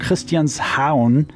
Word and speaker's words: Christianshavn: It's like one of the places you have to go to, and it's set in a place called Christianshavn. That's how they say it. It's Christian's Haven, basically.